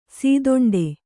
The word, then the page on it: ಸೀದೊಂಡೆ: sīdoṇḍe